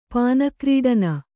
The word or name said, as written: ಪಾನಕ್ರೀಡನ